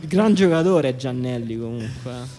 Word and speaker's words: Giannelli: No, però qui, però qui, ho scoperto che non si dice Giannelli, ma si dice Giannelli perché il, il padre l'ha chiamato, l'ha chiamato così in onore di Simone Giannelli, pallavolista italiano.